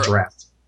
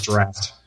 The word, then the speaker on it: draught: Okay, Aaron has just Googled how to pronounce D-R-U-G-H-T, it's pronounced draft. – D-R-A-U-G-H-T. – Yeah, that's what I meant to say. But it's pronounced draft.